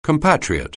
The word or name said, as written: compatriot